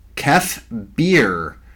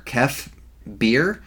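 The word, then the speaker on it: Kef Bir: Star Tours is going to be adding content from The Rise of Skywalker, including Kef Beer. I'm assuming that's how you pronounce it. It's K-E-F space B-I-R, Kef Beer.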